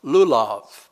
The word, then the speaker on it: lulav: The first is pronounced lulav, and that would have been a green closed frond of a date tree.